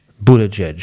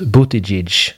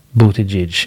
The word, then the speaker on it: Buttigieg: Amerikanerna, de kallar ju honom för Pete Buttigieg. Buttigieg är det officiella tv-uttalet. Men vi som kan maltesiska, vi vet att namnet uttalas Buttigieg. Buttigieg.